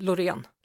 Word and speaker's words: Loreen: I was like hanging out with, I know you guys say Loreen, but in the UK we'd say Loreen. So Loreen, would I say? Is that the Swedish way to say it? – Loreen. – Loreen. I want to say it properly.